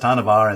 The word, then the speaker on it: Hanuvar: I just sent in a pretty please, whoever records it, don't let them say Volanus. It's Volanus. Hanuvar is pronounced Hanuvar and Volanus is pronounced Volanus, not Volanus.